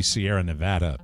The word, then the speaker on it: Sierra Nevada: Correct pronunciation, that should be Sierra Nevada Pale Ale.